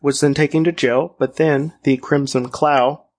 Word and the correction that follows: Cowl: Crimson Cowl. That's how you say it. Cowl.